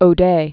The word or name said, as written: Anita O'Day